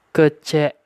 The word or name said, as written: Kecek